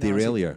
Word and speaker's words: derailleur: How to pronounce derailleur.